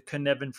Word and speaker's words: Cynefin: And then I would also read anything to do with the Canevin framework. So that's spelled Cynefin with a Cynefin.